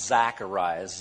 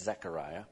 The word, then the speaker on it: Zechariah: It is not Zacharias, Z-A-K, Z-A-C. It is Z-E-C, okay? So it's Zechariah. It's one of the more commonly mispronounced books.